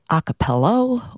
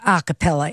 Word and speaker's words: a cappella: And that's a word that gets mispronounced a lot, too. A lot of people say a cappello or the dreaded acapulco. But it is a cappella, and thanks for playing along.